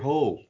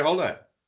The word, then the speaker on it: Hole: Actually, no, it's not actually pronounced Harry Hole, though, in the books, is it? – No. – No. – Don't tell me it's Holle. It is actually Holle. – It is Holle, yeah.